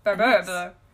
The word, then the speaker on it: Barbaro: Barbaro.